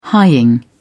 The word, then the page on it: hying: British English